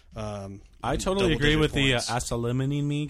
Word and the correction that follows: Kelechi Osemele: guy. I can't say his name. – Kelechi Osemele. – Kelechi Osemele.